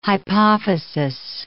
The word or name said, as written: hypophysis